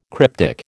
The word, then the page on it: cryptic: American English